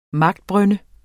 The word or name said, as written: magtbrynde